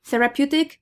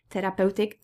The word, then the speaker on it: therapeutic: Therapeutic, be careful of pronunciation. It's not therapeutic, it's therapeutic.